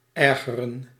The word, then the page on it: irritate: American English; [ˈɪr.ə.ˌteɪt]